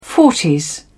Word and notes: forties: British English

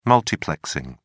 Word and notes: multiplexing: British English

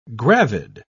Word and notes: gravid: British English

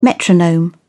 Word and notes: metronome: British English

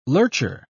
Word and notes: lurcher: British English

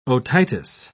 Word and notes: otitis: British English

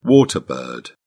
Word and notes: water bird: British English

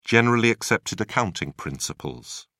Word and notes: generally accepted accounting principles: British English

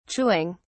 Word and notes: chewing: British English

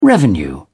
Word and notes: revenue: British English

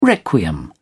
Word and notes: requiem: British English

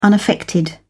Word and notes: unaffected: British English

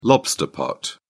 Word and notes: lobster pot: British English

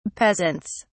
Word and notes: peasants: British English

